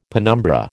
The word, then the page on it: penumbra: American English